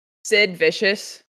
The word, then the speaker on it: Sid Vicious: Sid Vicious.